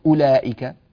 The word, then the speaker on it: أولئك: انظروا إلى كلمة أولئك، أولئك في الأذلين قبلها بقليل أولئك، نجد أن الواو عليها هذا الصفر المستدير، معناه أن الواو لا تنطق وصلا ولا وقفا، فليتنبه لهذا، بارك الله فيكم.